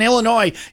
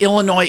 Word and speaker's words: Illinois: The irony of it is that Illinois itself has an S on it that you don't pronounce.